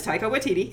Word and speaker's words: Taika Waititi: I've listened to so many reviews of this, and nobody knows how to pronounce his name. They just keep calling him the director. It's Taika Waititi.